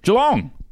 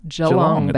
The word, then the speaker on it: Geelong: I think, is it pronounced Geelong? – Geelong, believe. – Geelong. – Geelong, baby.